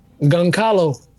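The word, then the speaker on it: Gonçalo: Is that how you say his first name? – Goncalo. – That's how you say it? I'm genuinely asking.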